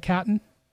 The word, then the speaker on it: Caton: You have a discovery like Miles Caton. – Awesome. – Yeah. Did I mispronounce this? Is it Caton? – I think you're right.